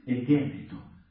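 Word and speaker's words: egéneto (ἐγένετο): Este verbo, el verbo gignomai, nosotros lo pronunciamos en una forma verbal que es, suena así, es egéneto.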